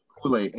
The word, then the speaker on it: Houle: Mark, M-A-R-C, Poulet, H-O-U-L-E.